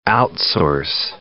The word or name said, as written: outsource